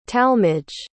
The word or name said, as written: talmage